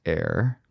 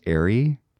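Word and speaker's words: Aire: So Kylie released the name of her baby boy, Air with an E, pronounced Air, because people were like, is it pronounced Airy?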